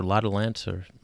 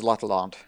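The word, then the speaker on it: L'Atalante: Is it L'Adolante or L'Adolante? – I think it's L'Adolante. – L'Adolante, okay. – The A's in French are very right.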